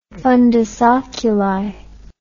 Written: fundus oculi

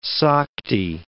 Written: Sakti

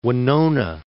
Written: Winona

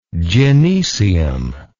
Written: gynecium

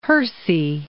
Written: Hersey

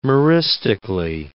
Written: meristically

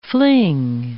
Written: fling

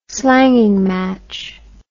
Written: slanging match

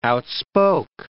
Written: outspoke

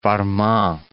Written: Farman

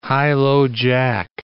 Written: high-low-jack

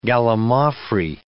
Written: gallimaufry

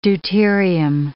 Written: deuterium

